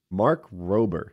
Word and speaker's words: Rober: Mark Rober. It's spelled like robber, but with one B.